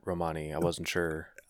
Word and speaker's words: Romani: It's spelled R-O-M-A-N-I. They're not connected to the Romans or to Romania, and in their language, the emphasis is on the first syllable.